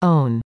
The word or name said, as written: own